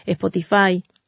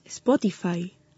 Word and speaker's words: Spotify: No, Nati, es Spotify.